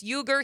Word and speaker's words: Uyghur: The correct pronunciation, I've seen it both ways. He is saying it's Uyghur.